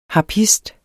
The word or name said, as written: harpist